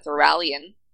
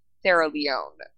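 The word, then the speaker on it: Theralion: Thoralion, which I learned is not pronounced Thera Leone, but...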